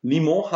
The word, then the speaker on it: Limoges: Limon Handball. Ich hoffe, ihr habt es richtig ausgesprochen. Limoges wird es geschrieben.